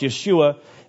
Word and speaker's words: Yeshua: Jesus is a name, which is of Hebrew origin and would be pronounced Yeshua.